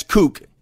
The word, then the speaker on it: kook: He's a strange cook. Oh, I mispronounced that. That's kook. He's a strange kook.